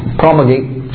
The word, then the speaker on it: promulgate: I just use promulgate, rhymes with perpetuate.